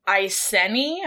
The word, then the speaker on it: Iceni: So she was a queen of the British Celtic tribe Iceni, I believe it's pronounced I-C-E-N-I. Iceni.